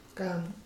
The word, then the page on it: buɽi: Gutob